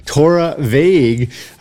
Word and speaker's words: Torabhaig: And now this Torabhaig, and that BH is... It comes out like a V as they pronounce it. And then the A-I-G-A-G-E is how it comes out in English, American English, Torabhaig.